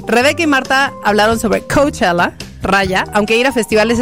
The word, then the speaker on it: Coachella: ¿Cómo se dice? – Coachella. – Coachella.